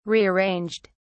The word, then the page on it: rearranged: British English